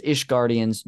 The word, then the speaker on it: Ishgardians: So, according to the item description, on a grade 2 Skybuilder soap, it seems that most, the way he pronounced it was, Ishgardians do not bathe regularly. – You nailed it.